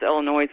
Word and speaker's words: Illinois: I do the same thing when somebody says Illinois. I'm like, that is not how you say it. Because I was born in Illinois. So I'm like, it's not noise. Like, that's not how that sounds.